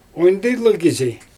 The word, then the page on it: ondijlyg kizi: Xyzyl